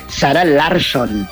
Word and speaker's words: Sara Larsson: Sara Larsen. – Sí, perdón que te voy a corregir, pero se dice Sara Larsson. – Larsson. – Okay. Larsson. Así, que así se pronuncia en sueco, Sara Larsson.